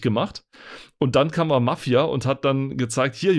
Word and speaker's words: Mafia: Mafia. Ja, bei uns war dann, bei, ja, im Deutschen liegt die Betonung eher auf dem F und in Amerika eher Mafia. auf dem A.